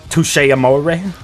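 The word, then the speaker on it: Touché Amoré: This album was co-produced by Jeremy Balm of the band Touche Amore.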